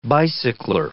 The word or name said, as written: bicycler